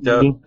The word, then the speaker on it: Doge: It's the Dodge meme. Anyone? No, dog meme. – Oh yeah, Doge. – Doge. There you go, Doge.